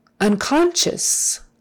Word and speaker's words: unconscious: That S-C-I is an S-H kind of a thing. O-U becomes like a short U. Unconscious, as if it were spelled S-H-U-S on the end. It's unconscious, unconscious. Accent is on the second syllable, unconscious.